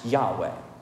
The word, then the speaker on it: Yahweh: but as near as we can tell, is pronounced Yahweh.